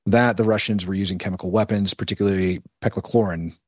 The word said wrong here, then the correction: chloropicrin: Did I pronounce that right? – Chloropicrin. – I always mess it up. Chloropicrin. Thank you very much.